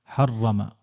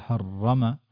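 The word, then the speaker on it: حرّم: كذلك حرم، شد يعني ربطه. وهذه العلامة اللي سين صغيرة يعني هذا الأول معناته حرف والثاني حرف ثاني، كان في المشدد بمثابة حرفين لكن في النطق جعل حرف واحد. حرم ولا تقول حرم ولا تقول، فلا تقول قل تعالوا أتلوا ما حرم ربكم عليكم، ولا تقول حرمت وإنما حرمت حرم.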